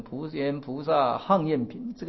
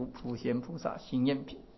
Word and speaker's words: 普贤菩萨行愿品: 那么后来呢慢慢的哎，这些古大德啦又加入的就是说哎，《华严经》的这个普贤菩萨这个行愿品啊，有的读成普贤菩萨行愿品，这个地方应该要读普贤菩萨行愿品。